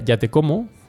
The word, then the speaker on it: Yatekomo: Eh, la marca es una marca muy creativa, como he dicho em, se pronuncia ya te como,